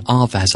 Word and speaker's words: Avaaz: There's another one, Avaz, I think it's pronounced, AVAAZ.